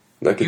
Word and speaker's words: Nakatomi: Nakatomi.